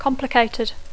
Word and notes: complicated: English

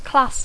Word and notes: class: English

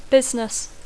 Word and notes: business: English

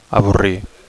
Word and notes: avorrir: Catalan

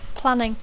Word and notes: planning: English

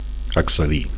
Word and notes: accedir: Catalan

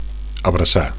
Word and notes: abracar: Catalan

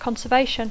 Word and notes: conservation: English

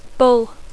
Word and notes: bull: English

